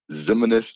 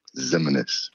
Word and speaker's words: Ximines: Ximines, the way I tell people to pronounce it is like reminisce. So instead of the R, just put a Z. Ximines. Reminisce Ximines.